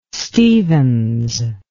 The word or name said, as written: Stevens